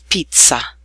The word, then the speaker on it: pizza: Double Z sounds just like a single Z, but held slightly longer. The words pizza, pazzo, piazza, and Abruzzo.